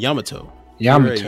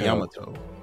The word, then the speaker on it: Yamato: Yamato. – Yamato.